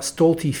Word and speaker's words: Stolte: A few years ago, we did a recording about Stolte Field and were contacted by Diedrich Stolte's niece. She wanted us to know that the family name is actually pronounced as Stolte or Stolt, not Stolte.